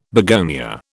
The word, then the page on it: begonia: British English